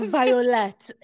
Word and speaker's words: Violette: I'm like, I have no idea how to pronounce this. – Yeah, Violette.